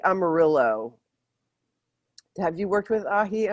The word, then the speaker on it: aji: Not A-H-I. A-J-I. Aji. Maybe you'd pronounce it. Not aji. Aji. Amarillo. A-J-I. And then the second word is A-M-A-R-I-L-L-O.